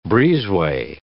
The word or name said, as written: breezeway